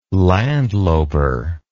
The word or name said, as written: landloper